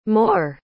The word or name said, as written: mohr